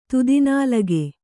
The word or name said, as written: ತುದಿನಾಲಗೆ